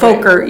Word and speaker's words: Fokker: That's why I was trying to put a long O on it so to take that away. – Fokker, yeah. Fokker. Okay, yeah. It's not as much fun as the other way to say it, but no, I think that's a...